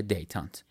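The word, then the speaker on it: détente: معادل تنش‌زدایی کلمه فرانسوی دتنت، حالا من تلفظ فرانسویشو حتما دارم اشتباه میگم، اما کلمه یه با دیکته دی ای تی ای ان تی ای که به انگلیسی تلفظش میشه دیتانت.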